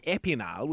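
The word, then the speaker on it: Épinal: Jared, she's in a little town at the moment called Epinal, which I'm obviously pronouncing horribly.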